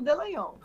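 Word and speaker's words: Dallagnol: É, é Delagnol que se pronuncia? É de... Eu falo Delagnol.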